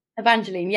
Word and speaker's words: Evangeline: Evangeline, yes, perfect.